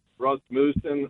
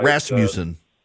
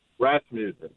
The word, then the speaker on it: Rasmussen: Hey, I had read an article about Rasmussen. I can't say it right. – Rasmussen. – Rasmussen. There you go.